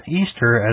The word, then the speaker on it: Ishtar: Easter, as we know it, comes from the ancient pagan festival of Astarte, also known as Ishtar, pronounced Easter.